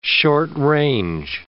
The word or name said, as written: short-range